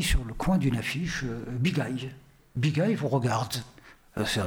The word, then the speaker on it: Bigaye: Voilà, donc Bigay, ça s'écrit B-I-G-A-Y-E, mais à l'oreille, on entend Bigay.